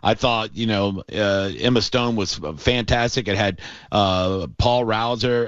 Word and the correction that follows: Hauser: Am I saying his name right? Hauser. – Paul Walter Hauser.